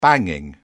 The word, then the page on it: banging: British English